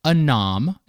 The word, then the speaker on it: Anom: How did Anom, and I hope I'm pronouncing that correctly, it's A-N-O-M, How did Anam work on the government side? What was happening? – Yes, you are pronouncing it correctly, although some in the US government say Anom as well, and then I get very confused in my head, and then I switch between pronunciations, but let's go with your one, because I'm pretty sure that's correct.